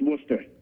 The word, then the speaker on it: Worcester: Well, I mean, when you think about Worcester, Worcester, Mass, they call it Worcester. Well, no, actually, Worcester. They don't even pronounce the R. – Yes, exactly. It's Worcester.